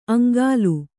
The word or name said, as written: ಅಂಗಲು